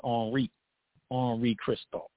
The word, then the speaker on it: Henri Christophe: The other lesser known figure, Henri Christophe, you know, the French, you know, will pronounce his name as Henri Christophe.